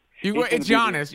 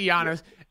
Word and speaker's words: Giannis: It's Giannis. Yeah, just say Giannis.